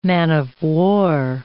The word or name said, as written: man-of-war